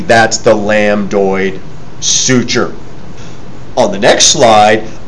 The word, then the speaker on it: lambdoid: It's called the lambdoid suture. The B is silent.